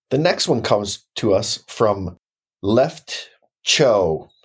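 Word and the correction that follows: Choux: C-H-O-U-X. I think that's how you pronounce it, right? – Shoe. – It's shoe. – Oh. – Like shoe pastry.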